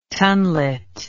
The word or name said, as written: tonlet